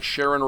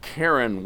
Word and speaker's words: Charon: Charon sometimes pronounced something more like Sharon or even a little like Karen.